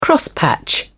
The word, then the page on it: crosspatch: British English